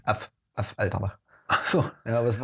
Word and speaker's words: Affalterbach: Ja gut, dann halt, was, wie Affalterbach? Af, Alter. Genau, so glaube ich, kann man es am besten aussprechen, weil okay. ein A, Doppel F. Ja, Af Alterbach.